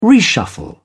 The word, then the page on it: reshuffle: British English